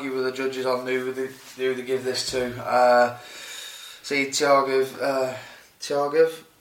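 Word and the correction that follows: Tukhugov: Tukagov. – Jesus Christ. Tukagov, yeah.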